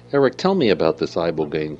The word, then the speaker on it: Ibogaine: He's had 20 years' experience in studying something called Ibogaine.